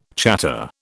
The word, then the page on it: chatter: British English